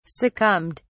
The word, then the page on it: succumbed: British English